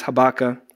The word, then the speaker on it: Habakkuk: So we're actually going to go into the book of Habakkuk. I think I said that right. Pastor Beth's not here, but she told me that it's Habakkuk.